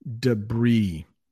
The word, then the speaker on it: debris: The next one, debris. That's how we pronounce that word in English. Debris.